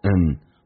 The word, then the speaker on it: and: is getting more and more serious， and 不被强调可以弱化成一个鼻音叫做 n， more n more and more serious，这句话就变成。